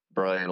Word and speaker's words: Bryan: And then on socials, it's at Brian Olson, B-R-Y-A-N.